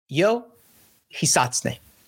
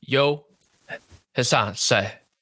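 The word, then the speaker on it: Ryo Hisatsune: Now, I cannot make those same noises, but it's something like Yo Hisatsune. – Yo Hisatsune. – Hisatsune. – Hisatsune. – They have a very silent R, and then it's like Hisatsune.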